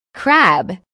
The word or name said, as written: crab